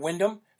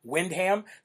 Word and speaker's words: Windham: Now, it's, by the way, it's not Windham. For everybody who does not live in New Hampshire, it is not Windham, New Hampshire. It's Windham. That's how the locals actually pronounce it. Just like if you come to New Hampshire, do not say Concord.